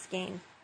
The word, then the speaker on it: skein: I got it for $10 a skein, and that's pretty good. Okay, I just said skein, and I think it's actually pronounced skein.